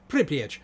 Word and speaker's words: Pripyat: There's a city called Pripyat. That's how you pronounce it, apparently. Pripyat.